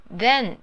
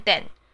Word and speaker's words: then: Then 那边很多人都会漏掉那个 th 的那个有点鼻音的那个音。 Then 不要只念 then，那很像是 d 的发音，这样念起来不专业。我们要专业一点。 Then，对，有那个鼻音。